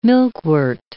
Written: milkwort

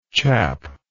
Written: chappy

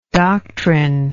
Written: doctrinist

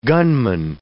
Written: gunman

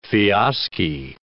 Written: fiaschi

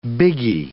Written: biggie

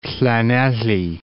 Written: Llanelly

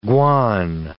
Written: guan

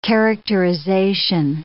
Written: characterization

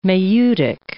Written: maieutic

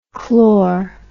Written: chlor

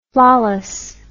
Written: flawlessness

